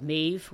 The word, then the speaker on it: Medb: Um, but and, and, the first book out is called Mave, which is spelled M-E-D-B.